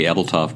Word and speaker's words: Ebeltoft: Williams, is part of the Ebeltoff Group. I'm saying that correctly, I think. – That is correct. – First of all, tell us a little bit about the Ebeltoff Group.